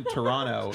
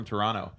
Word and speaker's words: Toronto: They say, oh yeah, I'm from Toronto. They say it without the hard T at the end, but Americans say Toronto.